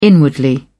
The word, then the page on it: inwardly: British English